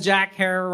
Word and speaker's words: Herer: and the superhero we know as Jack Herer rhymes with terror is born right there.